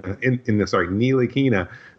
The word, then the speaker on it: Ntilikina: Ntilikina, that's how you officially pronounce it, I guess.